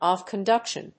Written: AV conduction

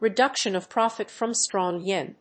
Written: reduction of profit from strong yen

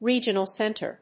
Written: regional center

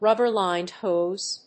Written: rubber-lined hose

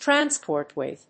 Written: transport with

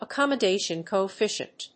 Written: accommodation coefficient

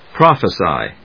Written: prophesy

